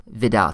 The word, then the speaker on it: Widal: It's spelled W-I-D-A-L, the Widal test, or Vidal test, depending on where you're from.